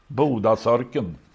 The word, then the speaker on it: Bodasörken: Men det ska uttalas på västgötska. – Ja, då heter det Bodasorken. Den vann faktiskt 8 lopp. Bodasörken.